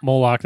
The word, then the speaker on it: Moloch: But what you'll find is that this, I don't know how to say it, Moloch. – Moloch, that's good.